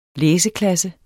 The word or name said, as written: læseklasse